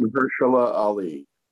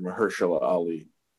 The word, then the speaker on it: Mahershala Ali: Mahershala Ali. – Yeah. – Two-time Oscar winner, Mahershala Ali.